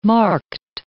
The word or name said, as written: marked